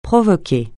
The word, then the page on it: provoquer: francais